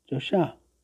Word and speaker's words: Joshah: Joshah. With the accent preferred on the last syllable,